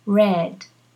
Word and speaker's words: read: The spelling is R-E-A-D, read, or R-E-A-D, red. You pronounce it as in red, the color, you know, the color red, or the word for communists, the reds, the communists.